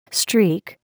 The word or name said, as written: streak